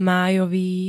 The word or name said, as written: májový